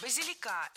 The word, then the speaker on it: базилика: Кстати, можно или базилика зеленого, или петруску. – Базилика – это неверно. Правильный вариант – базилика. Ударение ставим на третий слог, и там его сохраняем во всех формах.